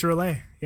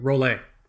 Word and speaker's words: Rolex: How do you say it? Is it Rolex? It's Rolex, right? – I'm pretty sure it's Relay. – Pretty sure it's Rolex. You're here, folks. Fuck them. It's Rolex. All right.